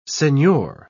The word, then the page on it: senhor: British English